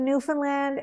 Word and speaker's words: Newfoundland: She went Ottawa to Newfoundland and now Sweden. – And by the way, nice pronunciation, Newfoundland, because people often get that wrong.